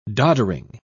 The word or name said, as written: doddering